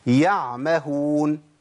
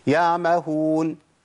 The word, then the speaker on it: يعمهون: يعمهون، أنا قلتها صح كده، اسمعوا كده وشوفوا العين هتختفي إزاي. يا مهون يا مهون يا مهون، أنا كده ما جبتش عين خالص، أنا أسقطت حرف من القرآن وجبت حرف بديل ليه ما أعرفش إيه هو.